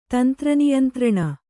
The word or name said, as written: ತಂತ್ರನಿಯಂತ್ರಣ